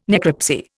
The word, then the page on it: necropsy: Indian